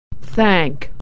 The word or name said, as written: thank